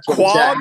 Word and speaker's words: quahog: The quahog, am I saying it right? – Yeah. Quahogs are great.